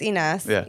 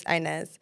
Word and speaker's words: Inas: I say Inez. – Well, in Arabic, it's Inez, you know, but in English, it's Inez.